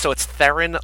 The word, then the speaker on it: Theron: No, and it's Theron, like Heron, like the bird.